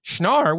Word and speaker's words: Schnarr: S-C-H-N-A-R-R has to be Schnarr. – That's Schnarr, yeah.